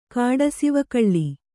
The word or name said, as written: ಕಾಡಸಿವಕಳ್ಳಿ